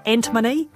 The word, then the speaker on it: antimony: Is it antimony? Antimony? Any ideas? I think both are acceptable, and in fact, I probably go between the two actually.